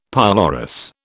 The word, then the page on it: pylorus: British English